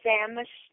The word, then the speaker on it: Samas: Oh, my name is Ann, A-N-N-E, Samas, S-A-M-A-S.